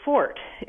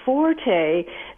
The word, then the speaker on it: forte: So it used to be that you would talk about your fort, you know, like you might say pronunciation is not my fort. But so many people have been pronouncing it forte that it's one of those words that,